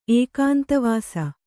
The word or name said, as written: ಏಕಾಂತವಾಸಿ